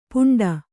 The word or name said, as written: ಪುಂಡ